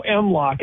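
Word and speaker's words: M-LOK: Go M-Lock. It rhymes with Glock.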